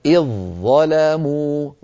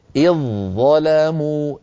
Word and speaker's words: إذ ظلموا: فنحول الذال عند الوصل إلى ظاء، وتصير هناك ظاءان، فندغم الظاء الأولى في الظاء الثانية ويصير النطق هكذا. إذ ظلموا، ظاء مشددة، لا يبقى للذال أثر عند الوصل.